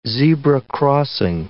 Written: zebra crossing

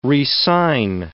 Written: re-sign

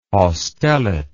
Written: haustellate

